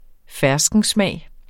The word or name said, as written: ferskensmag